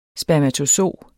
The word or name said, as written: spermatozo